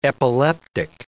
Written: epileptic

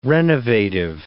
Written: renovative

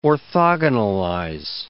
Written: orthogonalize

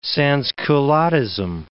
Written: sansculottism

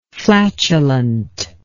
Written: flatulently

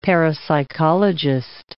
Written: parapsychologist

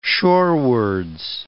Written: shorewards

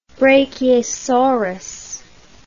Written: brachiosaurus

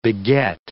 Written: beget

